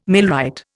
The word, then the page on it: millwright: Indian